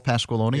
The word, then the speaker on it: Pasqualoni: Paul Pasqualoni.